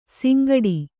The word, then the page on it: ಸಿಂಗಡಿ: singaḍi